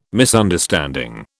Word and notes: Misunderstanding: British English

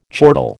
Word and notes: chortle: American English

